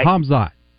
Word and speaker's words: Khamzat: The K is silent, though. Yeah, Khamzat.